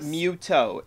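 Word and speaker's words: Mytho: Mythos. – Muto. It's pronounced Muto. Muto.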